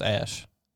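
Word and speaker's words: Æ: But he said it's pronounced Ash.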